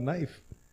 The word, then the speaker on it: knife: Think about knife. There's a whole ass K in there for.